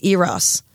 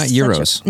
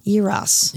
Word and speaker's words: eros: start of the relationship, and it's eros. – Nice. You said it right. – I did, and I don't... – It's not euros. – No, not euros.